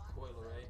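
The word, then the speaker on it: Coi Leray: How you pronounce it? Coolie Ray or Kylie Ray? Coyle Ray.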